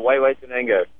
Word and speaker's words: Huehuetenango: The Guatemala Huehuetenango.